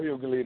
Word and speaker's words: Hughley: But DJ Hoogly. Am I saying it right? – Who is the comedian? – DL Hughley, the comedian.